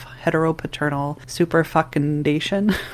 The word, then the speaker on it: heteropaternal superfecundation: They even went so far as to suggest Joanne's twins were the result of heteropaternal superfecundation, which is how you pronounce it, but feels...